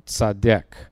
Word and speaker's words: tsadheq: The Hebrew word is the word tzaddik, which is spelled T-S-A-D-H-E-Q, tzaddik.